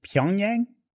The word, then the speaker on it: Pyongyang: Is it Pyongyang? – Yes. – Is that guy right? – Yeah. – All right, look at me. – Pyongyang.